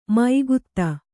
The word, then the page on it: ಮೈಗುತ್ತ: Kannada